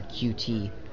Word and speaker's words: Qt: But one of the foundations, kind of the core foundations of the KDE program is something called Qt, or QT. People here seem to be pronouncing it Qt, so okay, Qt.